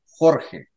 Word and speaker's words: Jorge: I mean, my name is pronounced in Spanish, Jorge. Okay, it's quite difficult probably for an English-speaking person, but George is fine.